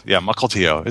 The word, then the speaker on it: Mukilteo: Mukilteo.